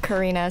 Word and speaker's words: Carina: P-A-T-T-I-G-R-E-C-O. Follow me. – I'm at Karina, C-A-R-I-N-A, last name, H-S-I-E-H.